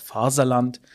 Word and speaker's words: Faserland: Und das ist einfach alles eigentlich nicht wahr, sondern es gibt ein Deutscher, der kann nicht aussprechen, wie man Englisch Fatherland sagen würde, sagt einfach Faserland.